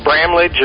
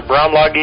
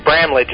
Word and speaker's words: Bramlage: Well, generally said Bramlage or Bramlage if you're German. But Bramlage is how it's said, and as I told Judy, everybody calls me Dr. B.